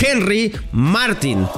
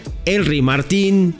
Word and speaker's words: Martín: Tu apellido tiene acento en la I y eso nos confunde mucho porque cualquiera diría, pues güey, le decimos Martín y no pasa nada. Y él me dice, sí, lleva acento, pero se pronuncia Martín.